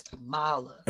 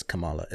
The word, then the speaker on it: Kamala: And for those who don't know, it is Kamala, it's not Kamala. You know, it's not Kamala, it's Kamala.